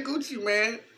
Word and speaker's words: Gucci Mane: That's how you say it. – Mane? – Mane, bro. That nigga say Gucci Mane.